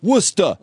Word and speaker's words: Worcester: It's Worcester, Mass. It's pronounced W-O-S-T-A. Worcester.